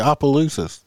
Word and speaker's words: Opelousas: It's Opelousas. – It's not Opelousas. It ain't like Oompa Loompa or something. – Yeah. I feel like I'm going to have a stroke trying to pronounce it. Opelousa? Opelousa? – No, we just told you Opelousas. – You got it all right. You got it all right except for the O.